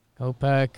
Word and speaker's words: Kopech: So, Gavin Lux, and then in the American League, it's Casey Mize and Kopech. Kopech, is that how you say it? – Kopech.